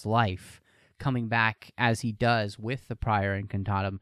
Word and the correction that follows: Priori Incantatum: Priori Incantatum. There's an I on the end.